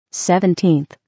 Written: 17th

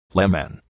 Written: lemon

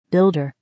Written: builder

